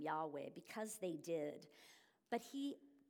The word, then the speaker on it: Yahweh: Remember We've mentioned it before, but anytime you see that capital L-O-R-D in your Bible, that is the English translation of the Hebrew. We don't know how to say it exactly since it was too holy for them to say, but we pronounce it as Yahweh.